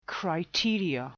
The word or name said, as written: criteria